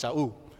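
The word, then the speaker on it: Shaul (שאול): But it's actually his Hebrew name, and it's pronounced as Shaul.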